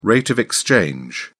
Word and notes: rate of exchange: British English

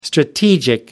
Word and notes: strategic: American English

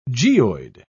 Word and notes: geoid: British English

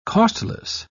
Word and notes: costless: British English

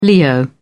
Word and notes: leo: British English

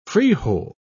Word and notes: frijol: British English

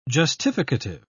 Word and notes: justificative: British English

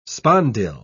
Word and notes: spondyle: British English

